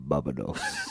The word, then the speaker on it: Barbados: I thought that as well, but now I'm looking and I'm thinking, maybe is it Barbados? Is that right? Am I saying that right? – Barbados. – Oh, thank you.